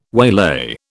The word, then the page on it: waylay: British English